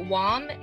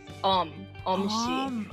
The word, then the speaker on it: Wong: Yes, it's Wong in English, but in Korean, it's Om-si.